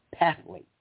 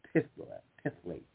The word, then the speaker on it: phthalates: Separate studies of parabens or waxes and phthalates or phthalates, depending on how you pronounce it. Excuse me, and the phthalates being spelled P-H-T-H-A-L-A-T-E-S.